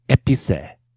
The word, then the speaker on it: épicé: Épicé, épé, épicé. – Épicé. – Épicé, the third one? – Yeah. – That is correct.